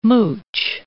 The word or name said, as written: mooch